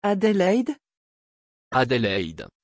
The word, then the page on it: adelheid: French